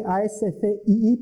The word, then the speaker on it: ASCII: Los dos más comunes son el código ASCII, se escribe ASCII, pero se pronuncia ASCII, ¿vale?